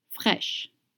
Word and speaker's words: fraîches: Look at that word, fresh, and do you see the accent on the I? It's called accent circumflex, and it doesn't change anything in the pronunciation. AI in French makes the sound E, frites fraîches.